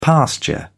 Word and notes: pasture: British English

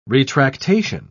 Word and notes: retractation: British English

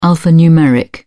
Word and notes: alphanumeric: British English

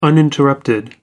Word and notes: uninterrupted: American English

